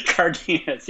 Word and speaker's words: Cardenas: Cardenas, yeah.